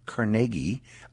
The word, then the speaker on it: Carnegie: Priscilla in Beaver, and because it's named after a person, I believe the correct pronunciation is Carnegie, Jerry in Carnegie.